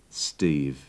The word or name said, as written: steve